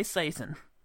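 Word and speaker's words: Seyton: It's Satan again. – Yes, Seton. – It is. – Sorry, it is. He pronounces it Seton. Some people say Satan, but this one's Seton.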